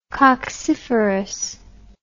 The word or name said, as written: cocciferous